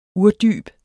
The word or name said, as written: urdyb